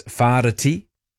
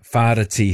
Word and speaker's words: Whāriti: The Big Hill in Palmerston North in the Manawatū region is known as Whāriti. I don't know how many people would say it like that, but that's the correct correct pronunciation, Farda T.